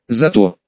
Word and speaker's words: Зато: «А меня зовут, зато, ударение на А.